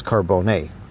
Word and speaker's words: Carbone: The E is pronounced A in Italian. But so really it's Carbone.